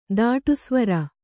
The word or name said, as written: ದಾಟುಸ್ವರ